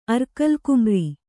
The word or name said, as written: ಅರ್ಕಲ್ಕುಮ್ರಿ